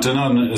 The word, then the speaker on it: Danone: Now, Danone is a company.